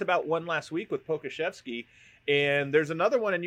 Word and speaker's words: Pokuševski: Okay, so it's Alexei Pokuševski. – Yeah, Alexei Pokuševski.